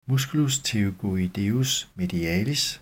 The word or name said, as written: m. pterygoideus medialis